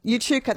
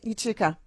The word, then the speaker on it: Echuca: It's coming up to 7 minutes to 7 o'clock, and the Paralympic swimmer from regional Victoria, from Ucha, how do you say that? E-C-H-U-C-A. Yuka. Yuchuka, that's it, Yuchuka, got it.